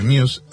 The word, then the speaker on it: Muse: o Muse se escribe, pero Muse, se largó en el año 2012 su sexto álbum de estudio que se llama The Second Law.